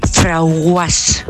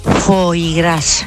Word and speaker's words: foie gras: Pues yo tenía una clienta que cuando me iba a comprar cierta cosa decía, dame un frauguas. A ver si sabéis lo que es eso, porque yo el primer día no lo sabía. Foie gras.